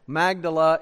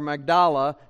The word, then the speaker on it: Magdala: Magdala, or Magdala, depending on how you pronounce it, that is on that western shore of the Sea of Galilee.